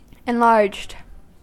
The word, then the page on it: enlarged: American English